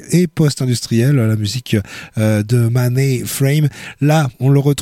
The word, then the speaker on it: Mahne: Musicien australien à l'instant, retenez son nom, c'est difficile peut-être, mais essayez, Mahnay Frame, ça s'écrit M-A-H-N-E, son prénom, Mahnay Frame,